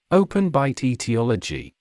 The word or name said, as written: open bite etiology